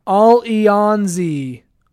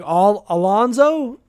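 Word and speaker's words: Allons-y: And Alice says her team name is pronounced All-E-On-Z, French for let's go. Okay, I did not know that. I had no idea. I was like, Alonzo?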